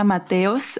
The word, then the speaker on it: Mateos: Well, yeah, my real name is Elena Mateos, but I go by Elena Matorg in every social media.